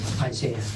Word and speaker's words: Isaiah: Isaiah. – How do you spell Isaiah? – I-S-A-I-A-H.